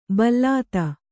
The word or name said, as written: ಭಲ್ಲಾತ